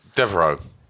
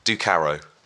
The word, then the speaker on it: Ducaro: Devereaux. – Yeah, not even close. You haven't even got the right letters. – Go on. – Ducaro. – Ducaro. – Yep.